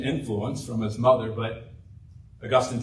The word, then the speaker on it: Augustine: I know the city in Florida that's named after him is pronounced St. Augustine, but his name properly pronounced is Augustine.